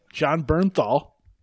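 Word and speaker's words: Jon Bernthal: We are connected, as always, with our, we're using our boy, Jelani's boy, John Bernthal.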